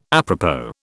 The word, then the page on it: apropos: British English